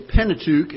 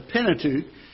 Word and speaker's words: Pentateuch: So the Pentateuch, and that is, if you want to write that word, it's spelled Pentateuch. Kind of unique spelling there. But the Pentateuch, and these are the first five books of the Bible.